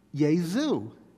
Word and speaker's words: Jesu: Up until the 16th century, English-speaking people usually called the Son of God by the name Yesu, which is spelled J-E-S-U, with the J pronounced softly like the letter Y.